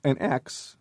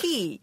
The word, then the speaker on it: chi: So an X to a Greek is what? – An X. – Chi. – No. – Chi. – Or pronounced. – Chi. – Oh, chi.